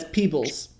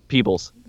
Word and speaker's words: Peebles: That's Peebles. – Peebles. Excuse me. You're so right. Excuse me. – Yeah. – I'm so sorry. How dare I think anything in Alabama would be pronounced how it's spelled? – How dare you disrespect the legend of Peebles?